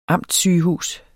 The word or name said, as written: amtssygehus